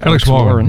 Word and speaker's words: Alex Warren: Alex Warren.